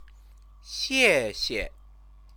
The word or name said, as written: xiexie